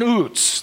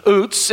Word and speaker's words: Uz: Job lived in Uz. That's how you pronounce the name in Hebrew, Uz.